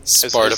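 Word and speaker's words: Spartakusbund: Or yep. Bund. – Spartabund. – Is the correct pronunciation.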